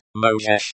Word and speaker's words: Можешь: Pronounced Thai means U-M-O-J-E-S-H-I-E-R-I. Можешь. Pronounced Mojesh means can or may vayeru beratayeri. Выбрать. Pronounced vibrat means to choose k-r-i-s-n-u-u.